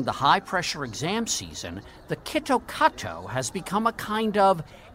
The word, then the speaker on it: Kit Kat: Kitto Kato, the Japanese pronunciation of Kit Kat, sounds an awful lot like Kitokatsu, which in Japanese means you surely will win.